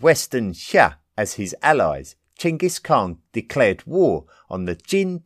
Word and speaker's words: Chinggis Khan: A man called Temujin had successfully unified the Mongol tribes under one rule, and he assumed the name Chinggis Khan, which has been traditionally pronounced in the English-speaking world as Genghis Khan.